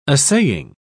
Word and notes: assaying: British English

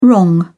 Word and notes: wrong: British English

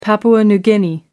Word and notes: Papua New Guinea: American English

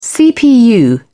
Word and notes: cpu: British English

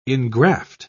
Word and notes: ingraft: British English

